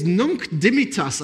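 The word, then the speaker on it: Nunc Dimittis: And it is Nunc Dimittas. I had to Google the pronunciation, so I'm pretty sure I got that right.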